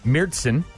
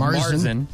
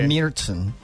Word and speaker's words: Märzen: As opposed to the Americanized Marzen. – Right. – Well, it's spelled M-A-R-Z-E-N, so right, you say... – It's Märzen. – It's Märzen. So I don't sound like a dummy when I go to Oktoberfest in Germany, if that ever happens.